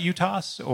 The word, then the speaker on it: UTAS: Yeah, and to be clear, do you actually pronounce it UTAS, or did I just make that up? – No, we do.